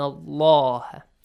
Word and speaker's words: Allah: And I will repeat that the word Allah, whenever there is a fatha sound before the word Allah or a dhamma sound, the word Allah, the lam, the little lam of the word Allah, will be pronounced heavily.